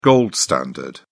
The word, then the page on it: gold standard: British English